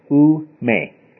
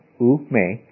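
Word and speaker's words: ou-me (οὐ μή): Because the Greek, what he has said is two-letter Greek words, ou-me, is how it's pronounced, ou-me.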